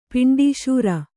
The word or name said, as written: ಪಿಂಡೀಶೂರ